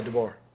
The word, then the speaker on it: DeBoer: I think Kalen DeBoer. Am I saying that right? – Yeah, DeBoer.